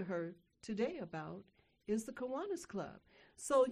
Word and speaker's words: Kiwanis: And she is the president, all hail the chief, of the of the Kiwanis Victorville of Victorville. Did I say that right? Kiwanis of Victorville.